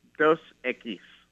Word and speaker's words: Dos Equis: Dos Equis. – Dos Equis. Dos Equis.